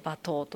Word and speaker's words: 馬頭: 馬の頭と書いて馬頭と読むんですけれども、